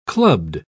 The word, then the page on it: clubbed: British English